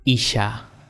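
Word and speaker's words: Isha (אישה): Pero mujer se llama o se dice en hebreo Isha. I-S-H-A. Isha. Así se dice mujer en hebreo.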